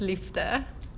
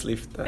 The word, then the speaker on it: liefde: Okay, so God is love in Afrikaans is God is liefde. – God is liefde.